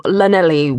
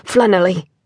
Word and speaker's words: Llanelli: "A Mr. Owen Thomas from Llanelli, Wales." – He pronounced it with an L sound, as in love. – "Llanelli," Owen corrected automatically, and entered the small room.